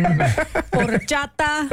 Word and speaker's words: horchata: You did great, except your pronunciation of Spanish words is lacking. Horchata. – Horchata. – Horchata.